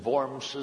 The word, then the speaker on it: Worms: W-O-R-M-S, pronounced Worms.